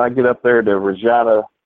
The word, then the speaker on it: Regina: I said Regina, the city that rhymes with fun.